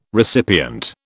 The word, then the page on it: recipient: British English